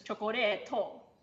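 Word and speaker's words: chokoreto (チョコレート): In Japanese it's chokoreto, but in Chinese it's chokori.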